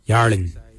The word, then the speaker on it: yarrliny: Narin is spelled N-G-A-R-I-N-Y. Narin is your ribs. For your skin, we say yarlin. Yarlin is your skin. Yarlin is spelled Y-A-R-R-L-I-N-Y.